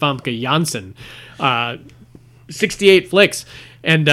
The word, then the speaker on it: Famke Janssen: But Yeah, the Dutch pronounce the J as a Y sound. Mm-hmm. So most people pronounce it wrong, but it is pronounced Famke Janssen.